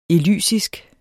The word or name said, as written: elysisk